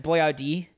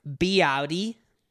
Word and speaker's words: Boyardee: So his name isn't Chef Boy... Isn't Boyardee. It's Boyardee. By butchering it.